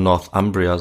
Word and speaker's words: Northumbria: Äh, wahrscheinlich würde man Northumbria sagen auf Englisch, ja. aber ich, auf Deutsch keine Ahnung.